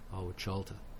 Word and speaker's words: Ahwhaolta: which is spelled A-H-W-H-A-O-L-T-A, Awachalta.